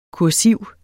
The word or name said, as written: kursiv